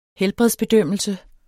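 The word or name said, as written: helbredsbedømmelse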